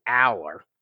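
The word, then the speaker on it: Allar: But I swear I heard Drew himself say Aller. So that's what I am going with, T.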